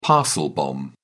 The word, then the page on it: parcel bomb: British English